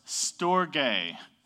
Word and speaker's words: storge: Okay, second word is pronounced storge. Everybody say storge. Okay, it's not storge, okay? This is part of the difference between Greek and English. There's different rules for how you pronounce things and stuff like that. No, it's not filet mignon, okay? So storge, okay?